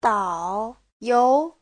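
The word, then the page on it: daoyou: dao3you2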